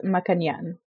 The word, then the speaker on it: Maccagnan: It is pronounced Maccagnan.